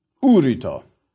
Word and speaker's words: urito: Thou shalt burn, urito. Why the difference in pronunciation? Fourth conjugation has a long I, and the third has a short I.